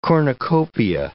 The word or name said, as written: cornucopia